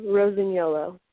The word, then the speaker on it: Rosignolo: My full name is Stephanie Rosignolo. – Ah, perfect. Okay, it's just like it's spelled, but I thought, oh my gosh, I'm not going to get that correct, and I don't want to say it incorrectly.